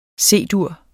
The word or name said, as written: C-dur